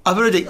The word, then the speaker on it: Ayurvedic: Yeah, Ayurvedic.